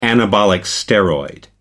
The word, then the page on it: anabolic steroid: American English